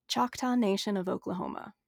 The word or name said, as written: Choctaw Nation